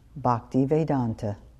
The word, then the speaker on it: Bhaktivedanta: When we were driving to Slippery Rock, I asked him to pronounce his name for me so I would have it right when I introduced him to my class. He said, Swamiji Bhaktivedanta. And then he proceeded to tell me what that meant.